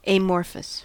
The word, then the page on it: amorphous: American English